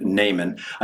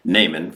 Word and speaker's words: Naaman: We're going to look at, as we consider the story of Naaman. I know some people pronounce it Naaman because it's N-A-A-M-A-N. You know, I've always said Naaman, and the right, the principle of commitment and compliance says, I don't like to change.